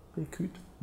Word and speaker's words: Rekhyt: Schreibt R-E-K-H-Y-T. Reküt.